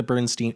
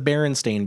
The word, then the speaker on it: Berenstain: But anyway, so yeah, is it the Berenstain Bears or the Bernstein? It's the Berenstain Bears. Like, that's the correct. Berenstain. – I thought it was the Bernstein. Like, it's the Bernstein like, advantage. – See, it's the... – People, like, pronounce it Bernstein, but the way that it's spelled is, like... – Like Berenstain. Like Berenstain.